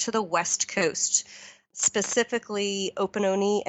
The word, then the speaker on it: Opononi: It looks like the way to pronounce them, that's how the correct pronunciation. When I first saw it, I thought it was Omapare and like Opanani or something, but they're about an hour and a half drive from Kerikeri.